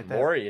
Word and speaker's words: Morey: Did I pronounce that one there? – Yeah, Morey, you nailed it, Dave.